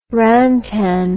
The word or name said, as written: rantan